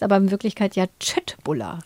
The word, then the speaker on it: Köttbullar: Die Schöttbuller, so werden sie, glaube ich, richtig ausgesprochen. – Ja, das stimmt. Das habe ich auch mal gelesen, dass alle denken immer Köttbuller.